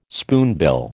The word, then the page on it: spoonbill: American English